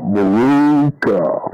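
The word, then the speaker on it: Maroochy: Impolitely, it's not Maruka. You pronounce it properly if you're a Brisbane folk by saying Moor, like the Moors of Africa. Ook ook ah. Moor ook ook. Ah, Maruka, like that. And that's how you say it. Moor ook ook ah. That's how you pronounce it, friends.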